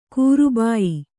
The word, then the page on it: ಕರಿಬಾಯಿ: karibāyi